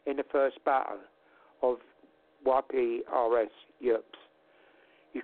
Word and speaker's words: Ypres: I don't know if, what, Yerps, whatever. – He's probably... Ypres. – Sorry? – Yeah, Ypres. – Ypres, okay. – That's how you pronounce it.